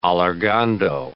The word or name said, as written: allargando